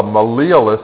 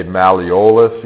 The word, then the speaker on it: malleolus: All right, there's a special kind of process called the malleolus that we find in the ankles. And I would pronounce it like that. Some people say malleolus, you can say that. Maybe say it both ways so you really see how it's spelled, for instance, on a practicum.